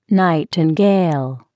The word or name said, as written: nightingale